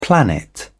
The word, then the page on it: planet: British English